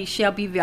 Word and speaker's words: Shelbyville: I don't pronounce it the way non-Shelbyvillians pronounce it. Mm-hmm. I say Shelbyville. I give it the three syllables mm-hmm. that it's supposed to have.